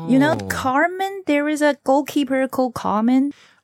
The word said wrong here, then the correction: Kahn: Oliver Kahn. – Oh, it's not Carmen. It's a Kahn. – Oliver Kahn. – Oh, that's a German yes. pronunciation, Kahn, not Carmen.